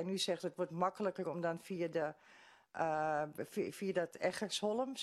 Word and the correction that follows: Engelsholm: zeg ik dat goed? – Ik woon op Engelsholm.